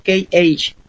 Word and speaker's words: KH: Me llamo, me llamo KH. – Como si pronunciaran la letra K de Kilo y la H, pero en inglés.